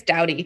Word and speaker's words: Doughty: But Caitlin, and I don't know exactly how to say her name. I think it's Doughty, D-O-U-G-H-T-Y.